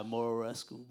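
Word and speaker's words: Muraresku: I'm pronouncing the Spanish way because I try to pronounce it in English. I get all tongue twisted, bro. Yeah, Murarescu.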